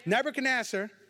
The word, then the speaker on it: Nebuchadnezzar: Chapter 3, Nebuchadnezzar. Did I say that right? Yes. She got me straight. Nebuchadnezzar, okay, turned around.